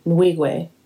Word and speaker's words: Nwigwe: So this week, Toby Nwigwe, I've been practicing how to say this, and I still did it wrong. – How do you spell it? – It's N-W-I-G-W-E, Nwigwe. – Okay. – See? Okay, cool. It came out good that time.